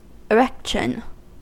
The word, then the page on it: erection: American English